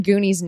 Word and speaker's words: Gunni: That's when we learn Goonie's name. – Yeah. Even though it's spelled G-U-N-N-I. And I'm like, that looks like Gunny.